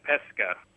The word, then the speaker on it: pesca: Pesce and pesca. Pesce as in it's fish, and pesca would be peach.